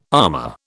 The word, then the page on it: amah: British English